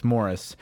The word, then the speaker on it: Morris: Um, and I say Maurice, I feel bad butchering that. – It's Morris.